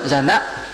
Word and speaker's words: Jhana: Chứ đọc đúng là Jhana, giật cổ lại, giật, na, na, nó mới cho kỳ, mấy tiếng Ấn Độ nó vậy.